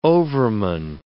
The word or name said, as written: overman